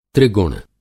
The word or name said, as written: त्रिगुण